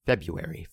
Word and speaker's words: February: Can we take that R out? It's not quite a silent R, but also no one really says the R exactly. February.